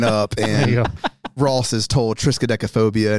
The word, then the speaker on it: triskaidekaphobia: but the one thing I thought of triskaidekaphobia.